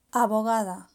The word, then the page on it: abogado: Spain